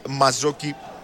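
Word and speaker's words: Mazzocchi: Η Νάπολη προηγείται του Άρη, ο Μπαρετίν και Μαζόκη, για να το προφέρουμε και σωστά.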